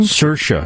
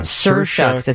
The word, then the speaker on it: Saoirse: But this this young woman, I can't, how do you pronounce her name? – Saoirse. – Saoirse. It's S-A-O-I-R-S-E. Mm-hmm. So Saoirse.